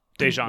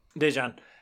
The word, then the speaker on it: Daejeon: Daejeon. – But Daejeon.